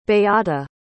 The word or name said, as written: beata